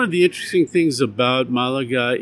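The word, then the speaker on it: Málaga: Comic-Con, I'm not sure if I pronounced it correctly, Malaga? – Yeah, Malaga. Yep.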